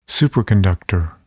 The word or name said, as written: superconductor